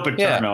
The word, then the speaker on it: Paterno: Joe Paterno. Am I saying that right? That doesn't sound right. – No, it's Joe Paterno.